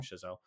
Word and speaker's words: Chazelle: I mean, like it's one of those movies where I'm like, this was a perfectly, and I mean, Damien fucking, how do you say his last name? Chazelle. – Yeah.